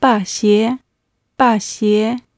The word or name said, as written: baxie